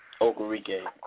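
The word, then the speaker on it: Okirike: Okurike.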